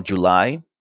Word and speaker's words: July: And Julie, no, July. July. And as for the rest, yeah, I mean the number was great.